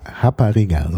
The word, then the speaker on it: Rapariga: Also Portugiesisch Rapariga, so ist ausgesprochen, das R mit dem H ausgesprochen im Brasilianischen, ist in Brasilien die Prostituierte.